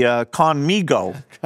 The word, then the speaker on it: Khanmigo: the KhanMigo, if I'm saying that right. – Yeah, that's right.